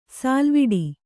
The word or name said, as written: ಸಲ್ಲವಿಡು